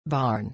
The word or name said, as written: barn